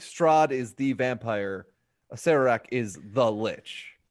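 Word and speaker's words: Acererak: Pronounced, hold on, I'm going to listen to it. Asarak. – Asarak, okay. – That's what this thing says. Okay, Asarak. I never noticed that extra R in there.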